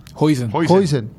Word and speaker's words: Huijsen: Huxen, ¿verdad? – Huijsen.